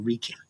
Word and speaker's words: Reiki: By the way, is it Reiki or Reiki? – Yeah, Reiki.